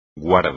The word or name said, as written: guarda